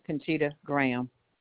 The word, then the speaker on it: Conchita Graham: Conchita Graham. – Spell that, please. – C-O-N-C-H-I-T-A. Last name Graham, G-R-A-H-A-M.